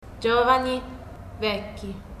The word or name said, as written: giovani-vecchi